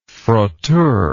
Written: frotteur

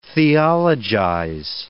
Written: theologize

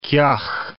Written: kiaugh

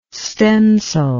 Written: stencil

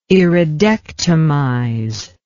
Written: iridectomize

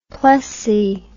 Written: plessey

